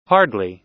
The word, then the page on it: hardly: American English